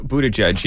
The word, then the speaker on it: Buttigieg: Buttigieg? – Buttigieg, yeah.